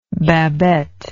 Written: babette

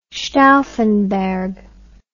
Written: stauffenberg

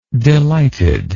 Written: delighted

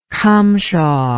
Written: cumshaw

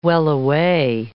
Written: wellaway

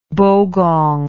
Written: bogong

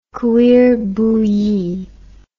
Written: cuir bouilli

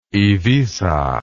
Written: Iviza

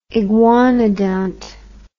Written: iguanodont

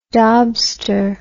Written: daubster

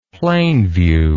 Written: Plainview

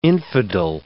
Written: infidel

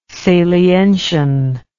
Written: salientian

